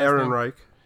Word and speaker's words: Ehrenreich: How do you say his last Ehrenreich. – Name? – Ehrenreich.